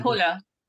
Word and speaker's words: Hüller: Now, of the others nominated, I think, and I hope I pronounce this right, Sandra Hüller. Hüller. – Sandra Hüller. – Hüller. Perfect. Thank you so much.